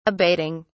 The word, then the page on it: abating: British English